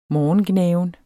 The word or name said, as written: morgengnaven